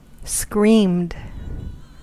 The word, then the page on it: screamed: American English